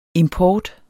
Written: import

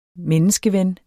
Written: menneskeven

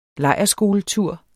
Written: lejrskoletur

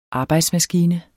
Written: arbejdsmaskine

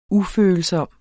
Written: ufølsom

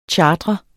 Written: CHARTREDES

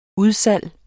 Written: udsalg

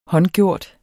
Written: håndgjort

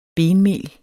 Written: benmel

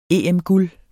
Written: EM-guld

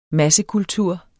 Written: massekultur

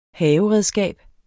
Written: haveredskab

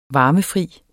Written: varmefri

